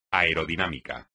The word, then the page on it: aerodinámica: espagnol